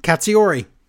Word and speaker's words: Katsuyori: Katsuyori.